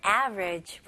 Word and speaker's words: average: 네, 다음은 average 볼게요. average 뜻은 평균의. 네, 발음 연습 한번 해볼게요. 이 단어는 average 이렇게 세 음절로도 물론 표현할 수 있겠지만 원어민들은 주로 이음절로 합니다.